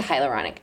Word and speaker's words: hyaluronic: Hyaluronic acid. – Correct. – I learned that recently. – It would destroy your skin. – Remember, guys, from last time? – I would just take the hyaluronic acid.